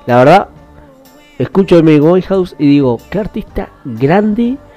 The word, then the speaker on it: Winehouse: Winehouse, ahí lo digo bien el nombre.